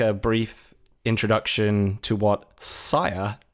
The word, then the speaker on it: Sia: Okay, so before people get on our asses on Twitter, it is pronounced Saya. It's spelled S-I-A. It's not Sia. We have clarified this, and we ourselves have been saying this wrong for a long, long time.